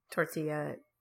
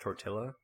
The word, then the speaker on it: tortilla: But they have these fantastic, just real, corn tortilla. – I believe it's pronounced tortilla.